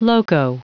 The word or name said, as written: loco